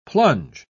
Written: plunge

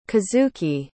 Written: kazuki

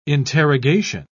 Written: interrogation

